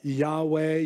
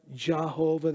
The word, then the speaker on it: Yahweh: We pronounce it uh, Yahweh, Yahovah, Jehovah. There's no hard sound like that in the Hebrew. So Jehovah is probably the least likely one.